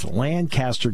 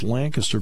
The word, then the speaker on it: Lancaster: I said, it's Lancaster, California, but it's Lancaster, Pennsylvania.